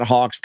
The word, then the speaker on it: Haack: uh, I saw Hacks punt. – Yep. – Uh, Haack, and I saw Haack. Sorry. – Yeah, yeah no worries. Sorry. – I, I, forgot my pronunciation.